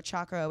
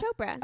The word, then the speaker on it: Chopra: And then like now it's like, you know, freaking Priyanka Chopra's there, or however you say her name. I always call it Chokra, Chakra, whatever. And I think it's Chopra.